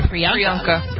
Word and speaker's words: Priyanka: Priyanka.